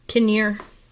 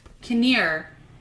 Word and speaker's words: Kinnear: is it Kinnear or Kinner? – Kinnear. – Kinnear. Yeah.